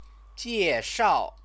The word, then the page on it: jieshao: Chinese